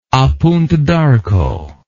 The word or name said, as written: a punta d'arco